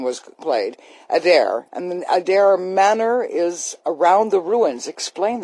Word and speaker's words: Adare Manor: Yeah, Adare Manor, yeah.